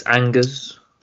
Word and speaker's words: Angers: But yeah, he plays Angers. – Nice pronunciation. – It's Angers.